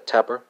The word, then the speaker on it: taper: It's going to be a Tapper, right? It's spelled T A P E R. Is it a Jake Tapper, right? – Well, not not spelled, pronounced as in Jake Tapper, but not spelled as in Jake Tapper because I think his last name has two P's, right?